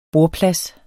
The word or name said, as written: bordplads